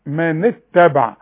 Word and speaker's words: اتبع: من اتبع، ما قلش من اتبع، الهمزة دية اللي فوقها رأس الصاد لا تنطق ابدا في عموم المصحف اذا كان قبلها كلمة او حرف، انما وضعت امام الحرف الساكن علشان العرب ما لا تنطق بحرف ساكن ابدا.